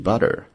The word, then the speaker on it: butter: 后面的 butter 黄油的意思呢，英式发音这个地方的 t 发为 d，而且呢，它会在卷舌头，所以是 butter。